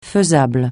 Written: faisable

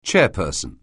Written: chairperson

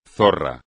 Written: zorra

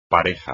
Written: pareja

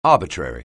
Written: arbitrary

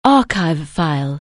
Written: archive file

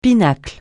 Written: pinacle

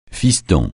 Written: fiston

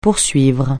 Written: poursuivre

